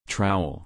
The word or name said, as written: trowel